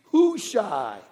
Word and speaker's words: Hushai: Everyone together, can you say his name with me? Hushai. That was good.